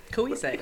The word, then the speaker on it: Cohasset: So in the 1870s, Amasa Jr. builds another mansion in, do you say it, Cohasset? – Cohasset, yeah. Rhode Island? Okay. I was looking at it and I was like, that's not how it's spelled, but I think that's how it's pronounced.